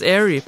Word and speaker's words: Aire: Air, ja, det må nok udtales Airy. A-I-R-E. Airy.